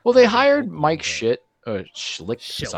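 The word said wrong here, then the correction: Shildt: Sorry, Schilt. Sorry, Mike. I was pronouncing it phonetically of how I saw it because Schitt's Creek.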